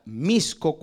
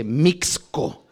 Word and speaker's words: Mixco: Ya me molestaba después porque me dice, Martín dice que se pronuncia Chela, pero luego me dijo que otro lugar se pronuncia Misco cuando yo leo que dice Mixco.